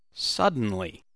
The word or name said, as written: suddenly